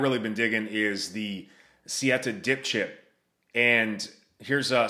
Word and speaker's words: Siete: Number one, and I'm at the website right now, I, I believe it's pronounced Siete, uh, S I E T E.